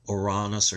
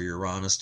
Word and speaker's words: Uranus: So Uranus or Uranus, depending on how you want to pronounce it, was given that name.